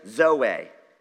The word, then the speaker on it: zoe: Biological life, bios, and there's zoe. That's how you pronounce that.